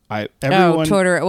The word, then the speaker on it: Totoro: Totoro.